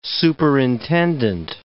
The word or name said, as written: superintendent